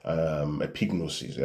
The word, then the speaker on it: epignosis: Epignosis, it's as simple as it sounds. Epignosis, let me spell it for you. Epignosis will be E-P-I-G, epignosis, N-O-S-I-S.